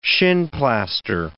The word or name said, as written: shinplaster